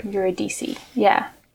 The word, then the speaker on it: Eurydice: Is that how you pronounce it? – I was always, would always go with Eurydice's, but I don't Eurydice. – Know why. – Okay. – Eurydice, yeah.